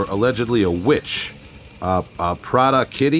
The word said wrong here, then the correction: Prattakitti: I help you to pronounce it. It is Prattakitti.